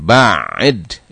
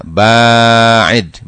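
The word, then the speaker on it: ba'id (باعد): Kata Rasulullah SAW dalam makna doa ini adalah Allahumma ya Allah ba'id, jangan salah bacanya. Ada orang yang mengatakan ba'id, itu salah. Yang benar adalah ba'id, ba'nya yang panjang.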